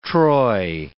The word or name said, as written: troy